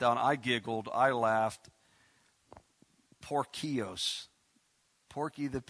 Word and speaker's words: Porcius: That's how you spell pronounce his name. It's Porcios. This is ridiculous. Paul's got all kinds of problems, and here comes Porky the Pig. That's really, Porcius is his name.